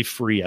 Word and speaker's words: Fure: but it's actually free. I don't know why I call it Fuhr. I call it Fuhr because it's spelled F-U-R-E. And I had the CEO on and he's like, it's called free. I'm like, he's like, look at it real closely.